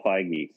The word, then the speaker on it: Feig: Yeah, dude, the dinner party episode is so funny for another reason, because it's directed by Paul Feig. I don't remember how to say his last name, but he directed Bridesmaids. – Oh, sorry, Paul Feige. Sorry.